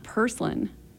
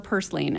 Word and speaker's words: purslane: Purslin or purslane. I mean, there's variation in the way that people pronounce this across the U.S.